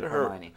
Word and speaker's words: Hermione: How do you say it? – Hermione.